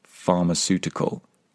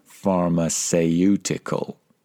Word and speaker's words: pharmaceutical: Now, just be careful with the pronunciation of this word, pharmaceutical. Don't be tempted to pronounce it phonetically, pharmaceutical. The vowel sound in the middle is very simple. It's just pharmaceutical.